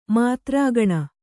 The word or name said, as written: ಮಾತ್ರಾಗಣ